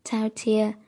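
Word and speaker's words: Thao Thia (饕餮): Thao thế nghĩa là háu ăn, phát âm là Thao Thia, là con trai thứ năm của con rồng huyền thoại, là một con quái vật tưởng tượng bí ẩn.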